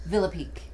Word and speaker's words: Villepique: Gene, how do you say your last name? – Villepique. – It is. Okay, so I'm right. – But it's not the that's the Americanized pronunciation.